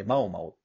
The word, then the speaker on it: 猫猫: うんその世界観で主人公に女の子、マオマオっていう、猫猫って書いてマオマオって読むんだけど、うん、その子が主人公で。